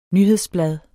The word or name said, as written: nyhedsblad